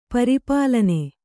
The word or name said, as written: ಪರಿಪಾಲನೆ